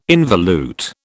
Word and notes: involute: British English

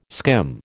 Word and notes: skim: American English

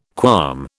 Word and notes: qualm: British English

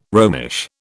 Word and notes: Romish: British English

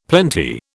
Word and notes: plenty: British English

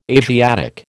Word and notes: Asiatic: American English

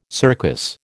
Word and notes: circus: American English

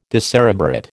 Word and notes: decerebrate: American English

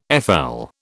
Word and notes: FL: British English

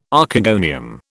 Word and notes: Archegonium: British English

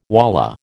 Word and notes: wallah: American English

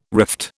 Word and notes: rift: British English

